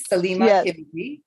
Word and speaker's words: Salima Kibidi: Yeah, my name is simple. It's spelt phonetically, Salima Kibidi.